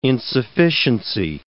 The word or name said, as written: insufficiency